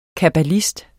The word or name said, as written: kabbalist